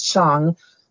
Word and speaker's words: Shang: And I like to put into my mnemonic here to remember that the A is long, as in father, it's Shang. Don't rhyme it with bang, because nothing in Mandarin Chinese rhymes with bang. If you're saying something in Chinese and it rhymes with bang, you're not saying it right. So remember that it rhymes with long.